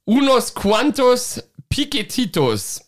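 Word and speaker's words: Unos cuantos piquetitos: Die schwebt hier so in der Luft über dem Geschehen und da steht drauf, und jetzt tut es mir leid, wenn die Aussprache nicht korrekt ist. Unos quantos piquetitos. – Ich glaube, so spricht man es aus, ja.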